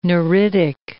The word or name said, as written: neritic